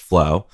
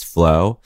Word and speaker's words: FLO: Me and Nick Laughlin were kind of like, oh, it should be called FLO, but it is pronounced flow. – Oh, okay. – In that tradition of like, three letters or whatever, and you say each of them individually, but it's flow.